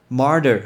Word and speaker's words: martyr: Martyr 很特别的发音是 M A R T Y R，这个叫 Martyr，Martyr。